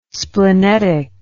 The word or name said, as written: splenetic